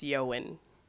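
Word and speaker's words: Ioan: It's, yeah, I think it's, is it Ewan or Ewan? – Yeah, it's Ewan.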